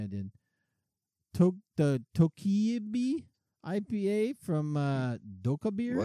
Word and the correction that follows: Dokkaebier: I feel like you're not pronouncing that right. – I probably am not. But as an Oakland brewery, but it looks like they're a Japanese brewery, but they're Oakland. And it's Dokkaebier. And they're a brewery out of Oakland.